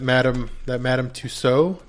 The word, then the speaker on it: Madame Tussaud: So here's some news that Madame Tussaud.